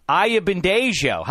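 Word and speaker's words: Ayanbadejo: And now he's going to be participating in his second straight AFC title game, the linebacker of the Baltimore Ravens, Brendan Ayabandejo. How do you like that pronunciation, first of all? Our Nigerian-born producer behind the glass coached us up to make sure we got the J just right.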